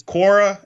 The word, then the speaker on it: Quora: It's Quora.